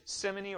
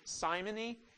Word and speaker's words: simony: So the first one of those is simony, and I might mention that this is pronounced either simony or some people pronounce it simony. Both are in the dictionary as appropriate ways of pronouncing that word.